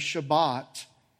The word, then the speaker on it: Shabbat: The word Sabbath, which the Jewish people pronounce as Shabbat, was viewed as a lasting gift from God for His people.